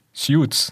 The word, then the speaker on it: Suits: Also er spielt sowohl in äh, Suits, Suites, wie wird es ausgesprochen richtig? Suits. Ja, ja, genau. Das ist, Suits.